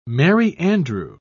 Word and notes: merry-andrew: British English

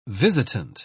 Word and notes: visitant: British English

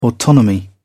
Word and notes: autonomy: British English

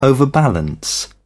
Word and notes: overbalance: British English

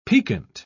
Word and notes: picquet: British English

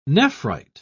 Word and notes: nephrite: British English

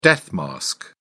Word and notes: death-mask: British English

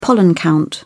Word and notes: pollen count: British English

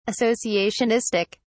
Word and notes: associationistic: British English

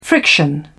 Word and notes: friction: British English

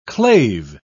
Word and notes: clave: British English

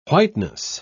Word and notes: whiteness: British English

